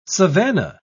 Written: savanna

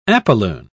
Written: apolune

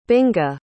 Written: binga

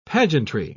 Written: pageantry

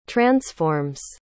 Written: transforms

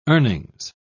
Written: earnings

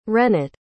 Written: renat

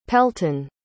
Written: pelton

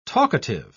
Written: talkative

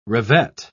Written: revet

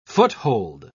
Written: foothold

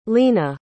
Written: lina

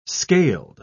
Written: scaled

